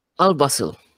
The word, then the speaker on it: Al Wasl: Al Asayel Road Warehouse Road. How do you say that? – Al Wasl. – What? – Wasl. It's called as Al Wasl Road.